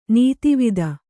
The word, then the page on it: ನೆತ್ತಿವತ್ತು: nettivattu; Kannada